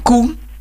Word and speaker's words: Kuhn: En Koen, K-U-H-N.